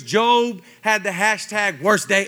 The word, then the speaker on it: Job: It's pronounced Job.